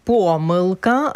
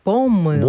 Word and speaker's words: помилка: Наше сьогоднішнє слово дня – помилка або ж помилка. – Блонд. – Ні, два Блонд. Наголоси допустимі. Це слово, яке має два допустимі наголоси.